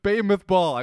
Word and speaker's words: Bamoth Baal: Next morning, Balak took Balaam up to Balak Baal. Bamoth Baal.